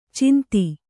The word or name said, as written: ಛಂದ